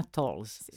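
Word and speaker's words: Towles: Les règles du jeu de Amor, si je prononce bien, Tolles. C'est bien ça? – Oui.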